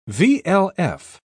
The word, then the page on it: vlf: British English